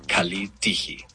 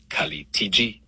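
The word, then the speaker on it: Kali Tiji (Καλή τύχη): En el griego moderno se dice Kali Tiji. Pronunciación aproximada, Kali Tiji.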